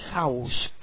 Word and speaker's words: House: Recordar que en español es la H no la pronunciamos, es un sonido sordo, mudo, pero sí en inglés lo pronunciamos con una J. ¿Ok? House, casa.